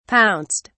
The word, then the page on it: pounced: British English